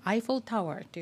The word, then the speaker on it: Eiffel Tower: まあ、もちろんパリにあるエッフェル塔なんですけれども、これ英語で発音するときはアイフォルタワーというふうにね発音するんですね。でこの記事が割とびっくりな記事で The title of this article is Eiffel Tower full of rust and in need of repair.